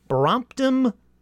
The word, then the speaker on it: Brompton: So let me double check the Brompton part of Brompton Cemetery right now. Oh, no, I didn't say. I said it right.